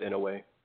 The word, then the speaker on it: Inoue: Definitely pronounced Inoue.